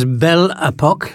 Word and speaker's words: Belle Epoque: Yeah, that was Belle Epoque, that's the right pronunciation, singing Black is Black.